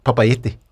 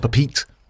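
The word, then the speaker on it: Papeete: Papaiti.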